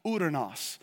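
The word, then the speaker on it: uranos (οὐρανός): That term is pronounced uranos.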